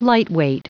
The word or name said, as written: lightweight